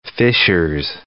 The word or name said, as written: Fishers